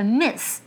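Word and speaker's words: Ms.: 那 Miss 来讲，发音是这样 Miss，OK？Miss 的话，就跟我想你那个 Miss 是有点类似。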